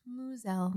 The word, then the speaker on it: Mosel: Spelled M-O-S-E-L. Pronounced Moselle. – Moselle.